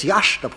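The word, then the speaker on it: Iași: Les jésuites, venant de Pologne, dans leur collège de Yassi, qui se prononce Yach, d'après ce que j'ai compris du président Teodorescu, les jésuites, dans leur collège de Yach, donc, forment les élites moldaves.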